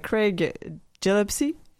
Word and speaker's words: Craig Gillespie: Donc, on va parler aujourd'hui du film I, Tonya, qui est paru l'an dernier, pardon, en 2017, réalisé par Craig Gillespie. Est-ce que je le dis comme il faut? – Oui. – C'est comme ça que ça se prononce.